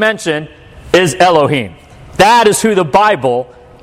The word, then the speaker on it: Elohim: And this is how you pronounce it, Elohim.